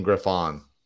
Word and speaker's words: Griffon: Wire-haired pointing Griffon. That's how you say it. Griffon.